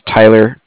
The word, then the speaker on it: Tyler: And my name was Tyler, T-Y-L-E-R.